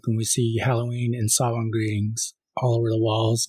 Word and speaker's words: Samhain: That's not at all the way you would think based on how it's spelled. Samhain? – Yeah, it's just spelled Samhain. So S-A-M-H-E-I-N.